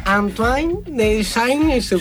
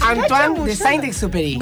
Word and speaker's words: Antoine de Saint-Exupéry: Antoine de Saint-Exupéry. – Yo le pregunté lo mismo fuera de aire. Menos mal que lo lee él y no nosotros. – Antoine de Saint-Exupéry.